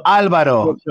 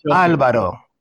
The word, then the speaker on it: Alvaro: If it's just 7, then it's not Alvaro. Alvaro. Yeah, Alvaro.